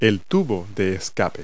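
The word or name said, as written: exhaust pipe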